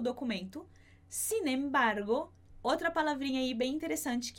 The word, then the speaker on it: sin embargo: Para você entender um pouquinho melhor esse sin embargo que a gente tem no espanhol, e a pronúncia a gente vai acabar juntando o N com o E. Então, sin embargo.